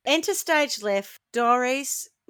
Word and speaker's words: Dorice: It's spelled D-O-R-I-C-E. You could call her Doris, I suppose. – No, I think you're right, Doris. – It is Doris,